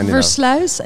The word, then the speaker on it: Versluis: by Eli Uitenbroek and Ari Versluis. Please correct me. – You're very close. – All right. – It's the Versluis and the Uitenbroek, but it's very close.